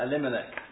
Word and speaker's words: Elimelech: Bethlehem a man called a limmer. Oh, I said it right in my head. See, when I'm putting the pressure. Uh, Elimelech.